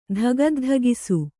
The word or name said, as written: ಧಗದ್ಧಗಿಸು